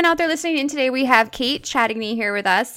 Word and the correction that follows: Chatigny: Close, it's Chatney. – Chatney. Okay, so the G is like silent.